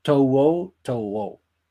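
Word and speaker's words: To'o To'o: porque además, eh, digo, para los que no estén viendo esto, su nombre se escribe, eh, bueno, su, apellido, mejor dicho, es T-O apóstrofe O y luego otra vez T-O apóstrofe O. O sea, Towo Towo. Algo así indican las guías de pronunciación, ¿no?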